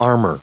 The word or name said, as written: armor